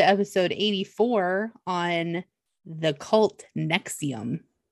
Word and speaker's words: NXIVM: It's spelled very weird. Nexium is actually a prescription drug. This is actually Nexium the cult. And it's spelled N-X-I-V-M.